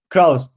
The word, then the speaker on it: Crous: Crous, but yes, very well done.